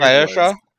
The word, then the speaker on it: Saoirse: Like, you get some weird Saoirse.